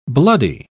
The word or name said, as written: bloody